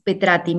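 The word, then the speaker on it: Petratti: Las redes más eh, que más me contactan es arroba Petrati.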